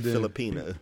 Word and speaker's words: Filipina: Am I saying it wrong? – Yeah, you're saying it wrong. – How you saying it? – Filipina.